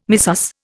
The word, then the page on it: missus: Indian